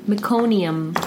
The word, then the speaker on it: meconium: Macaronium? – Macaronium. – What? – Meconium. – How do you spell it? – M-E-C-O-N-I-U-M as in Mary. Meconium.